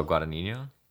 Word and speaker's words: Guadagnino: Oh, Guadagnino? – Thank you. Luca Guadagnino.